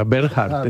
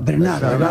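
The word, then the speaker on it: Bernhardt: Sea Bernhard, entonces. Sea Bernhard. – Todo el mundo dice Sara. – Sí, pero tienes que pronunciar. – En Francia, ¿cómo la llamamos? – Tienes que pronunciar a la francesa. Bernard.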